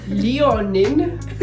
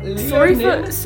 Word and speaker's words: Léonin: Léonin. Oh my god. Léonin.